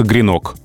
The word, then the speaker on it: гренок: А в единственном числе только гренок. Угу. Слово мужского рода. Ну, как щенок, например.